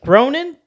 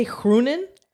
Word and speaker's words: Groenen: I think that's how you pronounce her last name because it's it looks like Gronin, but I've heard commentators say Groenen.